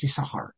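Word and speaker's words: Yisachar (יששכר): According to the Masoretes, it's Yisachar. It's always Yisachar.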